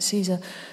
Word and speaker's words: Cesar: And the entry says, Caesar, C-E-S-A-R, so it might be Caesar, but I'm pronouncing it Caesar.